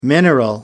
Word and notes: mineral: American English

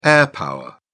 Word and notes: air power: British English

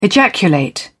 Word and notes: ejaculate: British English

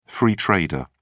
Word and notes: free trader: British English

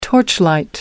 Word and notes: torchlight: American English

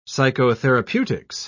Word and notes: psychotherapeutics: British English

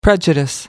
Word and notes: prejudice: American English

